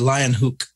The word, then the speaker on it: Leeuwenhoek: And Leeuwenhoek, or actually Leeuwenhoek, I think is how his name is pronounced.